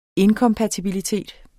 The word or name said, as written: inkompatibilitet